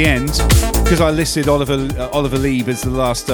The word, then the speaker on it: Lieb: And one of the guys I was into is a German producer called Oliver Lieb, L-I-E-B. Oliver Lieb. Only I pronounced it Lieb. Incorrectly, because I wasn't too familiar and well-versed with German surnames back in the day.